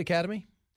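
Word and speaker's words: Academi: Nice Did I say that right, Academy? – Yeah, I sold that business 11 years ago.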